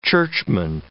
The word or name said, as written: churchman